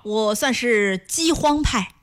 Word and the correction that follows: 饥荒: 你把这个荒念成轻声再来一遍。 – 饥荒派。